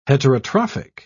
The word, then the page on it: heterotrophic: British English